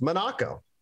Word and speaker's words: Monaco: said it's actually correct pronunciation is Monaco. And that always stuck well, with me.